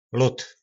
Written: lod

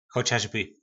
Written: chocasby

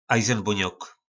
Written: ajzynbonok

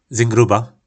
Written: Zyngruba

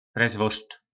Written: presvort

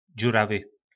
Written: dzuravy

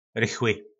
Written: rychly

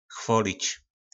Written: chvolic

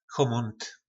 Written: chomont

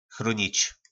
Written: chronic

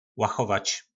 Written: lachovac

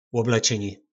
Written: loblecyni

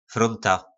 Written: fronta

